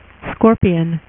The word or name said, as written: Scorpion